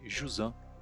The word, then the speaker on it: Jusant: E o U não tem acento, não tem nada, então você vai fechar um pouco, vai ficar Jusan, só isso.